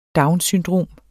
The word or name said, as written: syndrom